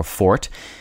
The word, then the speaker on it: forte: There is some controversy over how to correctly pronounce forte or fort. Common choices in American English are forte and forte, but many usage commentators recommend pronouncing the word as fort.